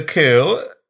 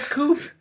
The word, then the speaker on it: coup: It's a coup. The P is silent, kind of like when I go. – Is it really? – Yeah. – I thought it was coop. – Only if there's an E on the end and that's a coop glass.